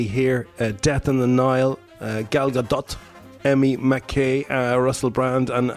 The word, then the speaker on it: Gal Gadot: I looked it up. That's how you pronounce it. Gal Gadot.